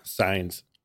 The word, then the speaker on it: Saenz: When I grew up, we called ourselves Saenz, but the correct pronunciation is Saenz.